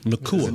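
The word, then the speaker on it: Nacua: Nakua.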